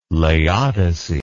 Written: laodice

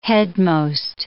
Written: headmost

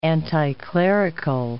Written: anticlerical

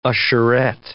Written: usherette

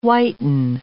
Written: whiten

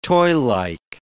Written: toylike

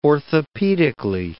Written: orthopedically

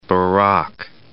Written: Barak

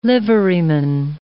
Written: liveryman

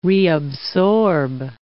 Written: reabsorb

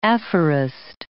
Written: aphorist

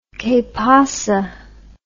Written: quepasa

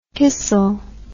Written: kissel

